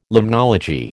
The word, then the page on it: limnology: American English